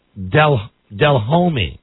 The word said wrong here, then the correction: Delhomme: When it's Delhomme, right? – Delhomme. – Delhomme.